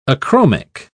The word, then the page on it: achromic: British English